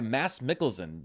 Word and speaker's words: Mads Mikkelsen: and Grindelwald is now played by Mads Mikkelsen. Yes, I looked up the pronunciation, and that's as close as I think I'm going to get to it.